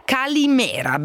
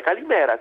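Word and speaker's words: Calimera (Καλημέρα): Calimera. – Ce lo ripeti bene? – Calimera, benissimo.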